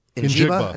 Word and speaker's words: Njigba: Njigba. – I think it's how you, yeah, Njigba is how you say his name.